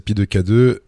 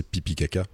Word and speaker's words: Pi2K2: On, peut prononcer Pi 2 K2. La vraie appellation, c'est Pipi Kaka. Pipi Kaka.